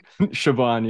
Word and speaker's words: Siobhan: Siobhan, yeah. – Yeah. It's a beautiful Irish name that a lot of people mispronounce all the time.